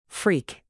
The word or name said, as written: freak